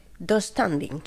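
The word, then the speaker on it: döstädning: Yo lo pronuncio... Dos standing. – Sí, así. Tengo esa silla de Ikea. Sí, pronuncia así.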